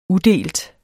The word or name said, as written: udelt